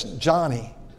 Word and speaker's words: Joni: It's spelled J-O-N-I, Joni, but it's Johnny.